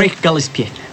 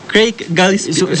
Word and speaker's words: Craig Gillespie: No, tú lo pronuncias mejor. ¿Puedes darnos el placer? – Craig Gillespie. – Craig Gillespie. – Supongo que es como francés el apellido. No, no sé de dónde es el director, pero el apellido suena como francés. – Pues exactamente, así como lo pronunció muy bien, porque yo digo Gillespie así muy feo, pero es lo que se logra con mi pronunciación.